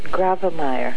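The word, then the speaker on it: Grawemeyer: You've won a new award, and my first question is, how on earth do they pronounce it? – I believe they pronounce it Gravemeyer.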